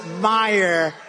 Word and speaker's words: Meijer: One of the 10 impeachers, a guy who spells his name M-E-I-J-E-R, but they pronounce it Meyer.